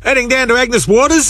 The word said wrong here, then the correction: Agnes Water: Mate, it's pronounced Agnes Water.